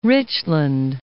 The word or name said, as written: Richland